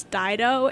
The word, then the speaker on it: Dido: and I pronounced the band who just sang the last song that you heard, Thank You, Ditto, and he was like, it's Dido, and make sure you don't get it wrong.